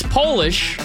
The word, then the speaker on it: Polish: Polish or Polish is the only word in the English language that changes its meaning and pronunciation when capitalized. So capital P, it's Polish.